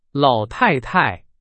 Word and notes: 老太太: Chinese

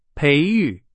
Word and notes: 培育: Chinese

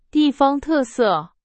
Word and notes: 特色: Chinese

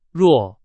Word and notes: 弱: Chinese

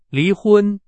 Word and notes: 离婚: Chinese

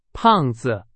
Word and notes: 胖子: Chinese

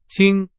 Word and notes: 亲: Chinese

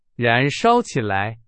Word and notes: 燃烧: Chinese

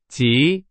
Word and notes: 极: Chinese

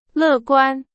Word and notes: 乐观: Chinese

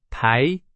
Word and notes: 台: Chinese

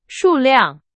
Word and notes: 数量: Chinese

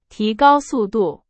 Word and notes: 速度: Chinese

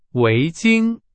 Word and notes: 围巾: Chinese

